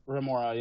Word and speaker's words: remora: Remoray. – Those are. – I am butchering it. – What did you say? – Remora.